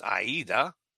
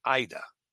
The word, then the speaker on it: AIDA: That is how you properly pronounce Aida. It's not and never has been pronounced Ida.